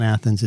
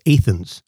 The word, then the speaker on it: Athens: So he and Athens is not Athens, it's Athens.